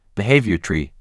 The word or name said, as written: Behavior tree